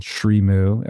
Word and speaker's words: Srimu: So Julie recently launched a plant-based cheese company called Srimu, S-R-I-M-U.